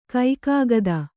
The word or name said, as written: ಕೈಕಾಗದ